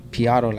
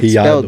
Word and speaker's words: Pillado: My last name is P. Otto. Like, it's Piotto. Spelled P-I-L-L-A-D-O. – So it's Anthony P. Otto. – Yeah, and that's just how you pronounce it. Uh-huh. So I kind of spelt it how it's pronounced.